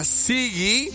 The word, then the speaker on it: Zigi: Va, entonces, manden ahorita, todos los que quieren participar tienen que mandar la palabra Sigi, que se escribe Z-I-G-I.